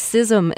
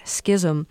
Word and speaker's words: schism: I can't remember what I've been corrected on most recently, but most recent correction, somebody wrote in and said it's schism and not schism. – Yeah. – So I looked it up. – I would say schism. – It's both.